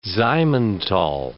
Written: Simmental

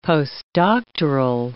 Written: postdoctoral

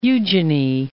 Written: Eugenie

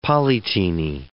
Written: polyteny

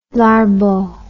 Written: Larbaud

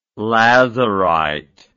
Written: lazurite

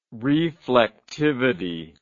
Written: reflectivity

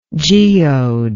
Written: geodal

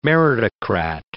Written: meritocrat

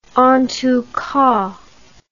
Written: entoutcas

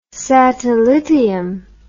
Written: satellitium

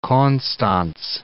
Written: Konstanz

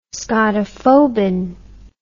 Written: scotophobin

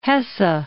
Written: Hesse

